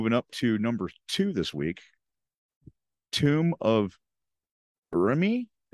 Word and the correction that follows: Urami: if I'm saying that one right, Urami. – Urami, so it's spelled U-R-A-M-I, and it's a rare from Saviors of Kamigawa.